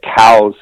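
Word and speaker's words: Coues: I mean, when you're talking about coos deer, now I'm going to say coos, and it's C-O-U-E-S is how you spell it. The actual pronunciation, you are correct. It was named after a guy named Elliot Cowes, who actually designated these deer and classified these deer, Elliot Cowes.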